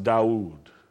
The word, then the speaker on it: Daoud: So like David in Hebrew and Arabic is pronounced Daoud.